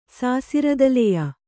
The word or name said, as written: ಸಾಸಿರ್ದಲೆಯ